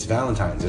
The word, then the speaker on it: Valentine's: Like, why can you not pronounce Valentine's? It's Valentine's. There's an N. It is an N.